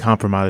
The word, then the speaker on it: kompromat: I think kompromat is kompromat.